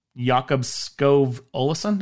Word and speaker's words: Jakob Skov Olesen: Jakob Skov Olesen, did I say that right? – Yep.